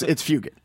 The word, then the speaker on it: Fugit: No, it's Fugit. – Fugit. – Yeah. – It's hard G.